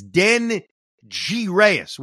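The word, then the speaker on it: Denyi Reyes: But now if you watch the SNY broadcast, we all now know that it's Den G Reyes. That's what it is now. I always thought it was Den Yi Reyes, D-E-N-Y-I Reyes.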